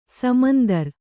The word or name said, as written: ಸಮಾಂತರ